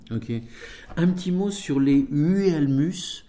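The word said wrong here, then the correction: Huemul: Les Uemul. – Je le prononce mal.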